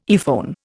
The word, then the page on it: earphone: Indian